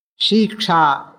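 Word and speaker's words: Shiksha (शिक्षा): Sikshyate anaya iti Shiksha. Actually, the right form is Shiksha, short to Shakara. She. It is not long, but in the book, Shiksha is there.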